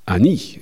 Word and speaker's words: Jean Hani: J-E-A-N is the first name. It's how you say Jean en français. – Jean. Got it. – Looks like Jean. And then his last name is Annie in French. You wouldn't say how do you spell that? – H-A-N-I. – Oh, like Scott Hahn. – Yeah, except it's not H-A-H-N. It's like, H-A-N-I.